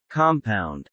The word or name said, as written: compound